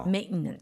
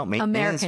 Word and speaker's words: Maintenance: Maintenance. – Maintenance. 그래서 여러분들 중간에 TE는 거의 생략하는 느낌으로 가주면은 발음이 조금 더 좋아지지 않을까, 그렇죠? – Maintenance, American style.